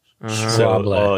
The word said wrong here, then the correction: Schwalbe: So, German listeners out there... Go ahead and send us a DM and let us know if I said it right. – Again, because obviously we didn't learn the first time. – Schwable. – Well, we call it Schwalbe.